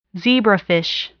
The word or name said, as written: zebrafish